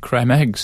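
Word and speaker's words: Creme Egg: There was an uproar about creme eggs switching to different chocolate. – Creme eggs? – Yeah. Cadbury's creme eggs. – Correct. That's the correct pronunciation.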